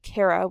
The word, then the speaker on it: Kara: Netbooks is spelled K-N-E-T, kind of like my name, Kara, with a K, K-A-R-A.